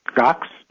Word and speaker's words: Gox: about Mount Gox, G-O-X.